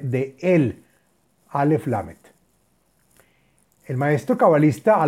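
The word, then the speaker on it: él (אל): Aleph Lamed que se pronuncia él y dice la página así.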